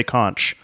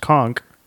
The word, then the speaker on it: conch: If you want to be mistaken for a Florida Keys resident or at least an island savvy regular visitor, don't ever say conch. The widely used word conch is pronounced conk, like a conk on the noggin.